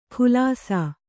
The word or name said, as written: ಖುಲಾಸ